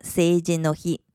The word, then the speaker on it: 成人の日: 因为 seijin 和 he 都是名词，所以中间要用 no 来连接，所以就成了 seijin no hi。